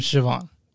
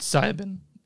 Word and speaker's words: Siobhan: By the way, it's his name Siobhan. That's how it's pronounced. – That's what I said. – You said Sibin.